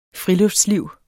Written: friluftsliv

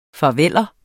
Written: farveller

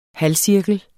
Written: halvcirkel

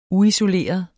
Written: uisoleret

